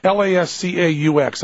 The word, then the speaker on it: Lascaux: Lascaux.